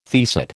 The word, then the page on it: teaset: American English